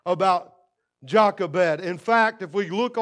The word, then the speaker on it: Jochebed: There was a woman named Jochebed, and that, as far as anything I can look up, is the correct pronunciation of her name.